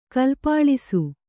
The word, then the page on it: ಕಲ್ಪಾಳಿಸು: kannada